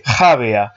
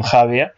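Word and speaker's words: Jávea: Bueno, Ale me dice que ahora lo pronuncié bien, Javea.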